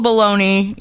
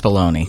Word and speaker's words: bologna: All right, so the Full of Bologna, even though it's spelled like the Italian way, Bologna, it's right. Pronounced bologna.